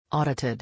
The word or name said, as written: audited